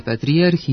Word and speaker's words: patrijarh: U nominativu ove imenice imamo akcenatske dublete s kratko uzlaznim akcentom na drugom slogu, patrijarh, s tim što dubletni oblik ima i postakcenatsku dužinu na posljednjem slogu.